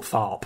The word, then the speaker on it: Tharpe: So like Thorpe, but Tharpe. – Yeah, Thorpe. – It sounds how it's spelled.